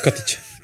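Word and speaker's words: Kotick: A jak się wymawia nazwisko tego pana prezesa? – Yy, się nie wymawia, bo po co? Kotić.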